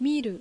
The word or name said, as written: Miru